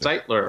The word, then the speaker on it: Stitler: How do you pronounce your last Stitler. – Name? Stitler, just like it's spelled.